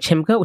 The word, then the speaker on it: Chimbga: You can find me on Instagram at Lorraine Chimga, which is one word, LORRAINE.